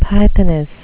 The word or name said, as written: pythoness